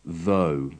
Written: though

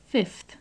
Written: fifth

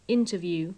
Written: interview

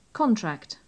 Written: contract

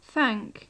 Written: thank

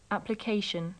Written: application

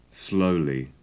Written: slowly

